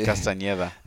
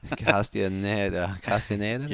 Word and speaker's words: Castañeda: I like the way you say Castañeda. – Castañeda.